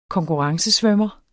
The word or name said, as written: konkurrencesvømmer